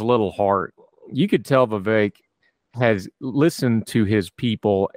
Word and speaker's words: Vivek: She did finally have the great one line about Vivek, rhymes with fake, rhymes with snake.